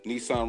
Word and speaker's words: Nesahn: I'm on YouTube with Nesahn Robinson. It's spelled N-E-S-A-H-N Robinson, R-O-B-I-N-S-O-N, Nesahn Robinson. And I'm on Instagram, Nesahn Robinson.